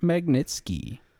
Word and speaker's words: Magnitsky: I'm going to butcher his name, but Sergey Magnitsky? Is that how we're saying it? – Yeah, Sergey Leonidovich Magnitsky.